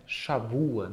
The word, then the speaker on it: shavua: Sábado em hebraico, o sétimo dia é shabbat, Semana é Shavua, né? Aí você pronuncia o B como se fosse U, mas é a mesma letra.